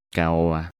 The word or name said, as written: Caoba